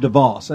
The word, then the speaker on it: DeVos: In 1926, Richard DeVos, I think I pronounced that right, D-E-V-O-S, he's the founder of Amway. No, Richard DeVos, that's how you say it, DeVos.